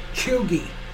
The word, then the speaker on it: Cheugy: Chuggy. C-H-E-U-G-Y. – Oh. I don't have an idea on that one. – Okay, the word pronounced Chuggy is used to describe someone who is untrendy, out of date, or trying too hard.